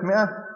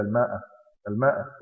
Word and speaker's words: مئة: اللي بالكم يا أخواننا كلمة مئة فيها الألف دي تكتب ولا تنطق، لأن بعض كثير من الناس يقول لك إلى الماء الماء ينطق الألف.